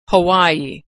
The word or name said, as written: Hawaii